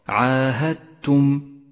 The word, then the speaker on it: عاهدتم: الإدغام المتجانس أيضا في كلمة عاهدتم، تدغم الدال بالتاء وتشدد التاء ولذلك لونت الدال بالرمادي لأنها لا تلفظ عاهدتم.